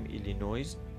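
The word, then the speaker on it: Illinois: ما میگیم ایلینویز شما هم که داری میگی دو تا تلفظ داری یکی ایلینوی و دیگری ایلینویز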